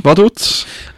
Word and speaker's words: Vaduz: Vaduz.